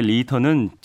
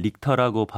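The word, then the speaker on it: Richter: 리히터 규모할 때 리히터는 찰스 프랜시스 리히터, 영어식으로는 릭터라고 발음하기도 하는데요. – 네. 이게 독일계 성씨예요. 그래서 리히터로 발음합니다.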